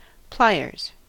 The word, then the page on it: pliers: American English